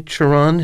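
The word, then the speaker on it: Cioran: I'm not someone who's known for his sunny disposition, and yet I'm very grateful to you for mentioning, do you say Chiron? His name is spelled C-I-O-R-A-N-E-M. – I've heard it. That's how I've heard it pronounced, uh-huh.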